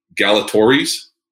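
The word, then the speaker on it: Galatoire's: And I'm going to mispronounce it. You got to make fun of my California vibe here. Galatori's. – No, you got it.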